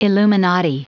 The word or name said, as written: illuminati